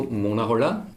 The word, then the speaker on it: Mona Holler: Und wir heißen als Duo Mona Holler, mit Betonung auf O, weil wir dann gesagt haben, es ist ja ziemlich ein Holler und nicht Mona Holler, sondern ein Mona Holler.